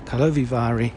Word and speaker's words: Karlovy Vary: there you go, that's how you pronounce it, Karlovy Vary International Film Festival.